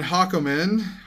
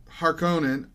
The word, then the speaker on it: Harkonnen: Beastrabon Hakomen. Harkonnen.